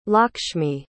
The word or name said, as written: Lakshmi